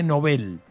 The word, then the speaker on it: Nobel: El otro día escuchaba ya premios Nobel en Estados Unidos. Más de 400 ganadores hubo en la historia del premio Okay. – Nobel. Que dicen que se dice Nobel.